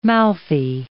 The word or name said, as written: mouthy